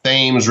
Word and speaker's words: Thames: They call it the Thames River here in Connecticut, but it is spelled like Thames, like in London.